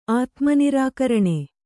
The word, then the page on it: ಆತ್ಮನಿರಾಕರಣ: Kannada